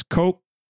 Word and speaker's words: Ko'olau: Okay, so it is K-O-apostrophe-O-L-A-U. So O's make the O sound. A makes the A sound, and U makes the OO sound in the Hawaiian alphabet. So it's, and the apostrophe is a glottal stop, I think. I think that's how you say it. Anyway, it means you hard stop. So it's Ko'olau.